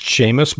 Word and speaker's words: Seamus: It's pronounced Seamus, but it's spelled S E A M U S.